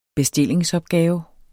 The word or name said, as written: bestillingsopgave